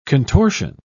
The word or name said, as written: contortion